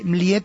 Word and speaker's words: Mljet: Esta se escribe más raro, se escribe M-L-J-E-T, la J como I, ¿no? Y Mliet